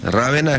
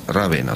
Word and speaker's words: Ravenna: Rávena es la forma extendida, en español, con acento en la A, y la pronunciación etimológica italiana es Ravenna. O sea, Ravenna en castellano, Ravenna en italiano.